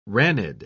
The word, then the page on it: ranid: British English